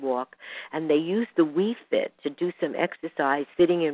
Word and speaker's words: Wii: One of the things that we've learned is that there was a research study using something called the WeFit. I don't know if any of you are familiar with it, but the We is spelled W-I-I.